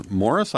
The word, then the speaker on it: Morris: Morris, I'm sorry, have I got your name wrong? – No, that's right.